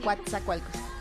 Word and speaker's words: Coatzacoalcos: Y así se pronuncia Coatzacoalcos.